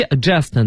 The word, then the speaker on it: adjust: 对，所以是 adjust 呢，它前面有个 D 啊，大家就不发音了，但 AD 呢是个强调，那就是让你变得特别的正确。